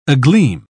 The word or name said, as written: agleam